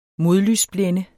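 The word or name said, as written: modlysblænder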